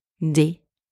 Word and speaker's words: rendez-vous: And finally, the letter S at the end of that word is completely silent. So, if we are putting all of these sounds together, we now have rendez-vous.